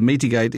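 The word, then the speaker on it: Metig8: I take it that Metigate is a play on mitigate. Is that right? – It is. It's a play on words. – It's spelt M-E-T-I-G and the letter, the figure 8.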